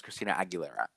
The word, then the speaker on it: Christina Aguilera: I want to barf how you said her name. – Okay, I just say it like that. Okay, I know it's Christina Aguilera.